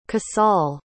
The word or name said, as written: casal